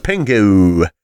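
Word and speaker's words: Pengo: And that's actually how you pronounce it, Pengu.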